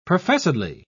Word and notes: professedly: British English

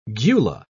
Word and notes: gula: British English

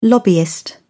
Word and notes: lobbyist: British English

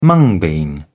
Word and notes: mung bean: British English

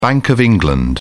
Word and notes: bank of england: British English

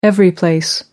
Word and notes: everyplace: American English

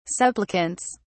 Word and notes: supplicants: British English

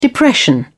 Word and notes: depression: British English

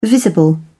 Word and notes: visible: British English

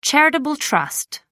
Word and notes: charitable trust: American English

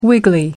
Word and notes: wiggly: American English

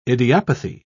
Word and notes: idiopathy: British English

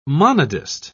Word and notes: monodist: British English

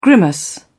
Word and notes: grimace: British English

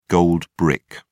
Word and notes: gold brick: British English

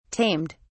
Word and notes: tamed: British English

